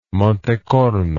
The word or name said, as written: Monte Corno